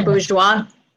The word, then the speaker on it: Bourgeois: So, do you pronounce it Bourgeois? – Yes.